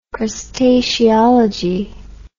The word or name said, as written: crustaceology